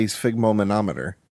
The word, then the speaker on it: sphygmomanometer: It's not every day you get to say sphygmomanometer. – Sphygmomanometer. – There you go. – Sphygmomanometer.